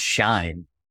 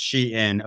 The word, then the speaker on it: Shein: I thought it, I thought it was called Shine. I actually just Googled it, how to pronounce. So I didn't sound like an idiot. It is pronounced Shein, according to Shein officials' Twitter.